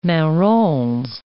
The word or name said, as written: marrons